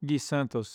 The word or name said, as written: Gui Santos